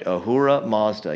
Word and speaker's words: Ahura Mazda: It's actually a Persian title used of the Persian high god called Ahura Mazda. Yes, I said that right. Ahura Mazda. You're like, well, that sounds like the car company.